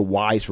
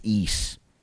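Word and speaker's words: Ys: I used to call that shit Wise for fucking years. Then when I met Riku, it turned out it's East. Like, okay, East.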